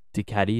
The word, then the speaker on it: Ducati: I need to stop saying Ducati. For some reason, I've grown up thinking you guys say Ducati, but it's Ducati, it's a Ducati. D, but I pronounce it as a J.